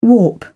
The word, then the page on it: warp: British English